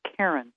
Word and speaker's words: Caron: My email is my name, which is spelled like car on, Karen, C-A-R-O-N, 30.